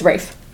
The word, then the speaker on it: Ralph: Spelled Ralph. – Yes. – Pronounced Rafe.